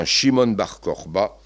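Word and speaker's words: Shimon Bar Kokhba: Elle est menée cette fois par un certain Shimon Bar Korba. C'est ainsi que l'on prononce son nom aujourd'hui.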